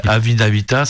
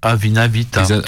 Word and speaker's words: Avina Vita: Et j'arrive pas à le dire depuis le début, j'arrête pas de me tromper, mais Avina Vita, c'est comme ça se prononce. Avina Vita.